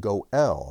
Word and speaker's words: go'el: And we can also pronounce this word go'el, and that's the participle.